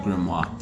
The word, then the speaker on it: grimoire: Uh, grimoire. Grimoire. Like think noir. – Yeah. So it would be grimoire. Excuse me. Grimoire. Okay. – I always call it grimoire.